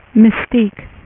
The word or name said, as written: mystique